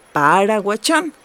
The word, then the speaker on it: Paraguachón: ¿Paraguachón? ¿Lo dije bien? – Así es, capitán. Ya te lo aprendiste. Regresamos a Paraguachón.